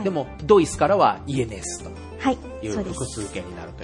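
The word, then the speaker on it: yenes: イエネス、円はイエネスって言うんですね。 – はい。そうです。 – 円じゃダメなんですか? – イを発音して、うん、イエンって言うんですけれども、はい、ここは複数形になっているのでイエネスになります。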